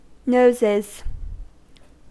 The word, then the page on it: noses: American English